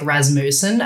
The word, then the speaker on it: Rasmussen: Sometimes people want to go with like the full Danish pronounce, which is like Rasmussen. And I'm like, no. We boganized it in my family.